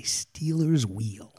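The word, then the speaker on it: Stealers Wheel: How do you say the name of the band that sung Stuck in the Middle with You? – I say Steeler's Wheel. – Oh, there you go. You got it.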